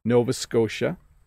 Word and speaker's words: Nova Scotia: Nova Scotia's fairly straightforward. Nova Scotia. But the T isn't really a hard T, is it? Scotia.